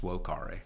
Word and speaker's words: vocare: Actually, it'd be pronounced vocare, because I was in Latin club.